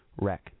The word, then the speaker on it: Wreck: And the reason, by the way, I pronounce it like that is because it's fat, like, you know, a fat person. Rec, W-R-E-C-K, chords. Chords, like, of a musical instrument.